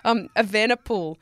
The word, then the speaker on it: Evenepoel: He's not even a Pole. No. Evenepoel.